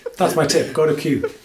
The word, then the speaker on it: Kew: There's a great little park in town called Q. Q? Q? – Yeah, K E W. Is that how you pronounce it? – K E W Q. Q. Yeah. Yeah, Q.